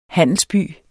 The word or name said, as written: handelsby